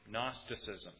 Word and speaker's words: Gnosticism: Gnosticism. So the G is silent, not Gnosticism, but just Gnosticism.